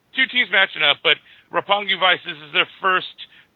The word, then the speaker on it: Roppongi: Did I say that right? Roppongi. – Yep, it's the district in Japan where they party their asses off.